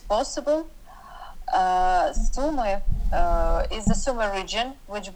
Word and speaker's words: Sumy: Tell me about how you're feeling at this time, you know, about your family you had to leave behind in Sumy. Am I saying that right? Sumy? – Yeah, it's Sumy.